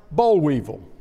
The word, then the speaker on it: boll weevil: At the top of the monument is a large boll weevil. Yes, I said that right.